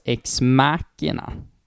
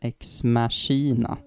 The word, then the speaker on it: Ex Machina: Och sen har vi den som jag faktiskt tror uttalas Ex Machina. Även fast man tror att den uttalas Ex Machina.